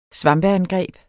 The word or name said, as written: svampeangreb